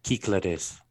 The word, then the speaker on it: Cyclades: So, one of the listeners gave us a little bit of a tutorial on how to say it in Greek. – Ah. – How you pronounce it in Greek. – Cyclades? – So, yeah, we pronounce it cyclades, and he's like, it's you can say it this way.